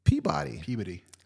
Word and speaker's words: Peabody: P-E-A-B-O-D-Y. – Peabody.